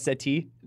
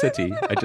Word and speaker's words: settee: Is that how you pronounce it? Not settee? – Nope, it's settee.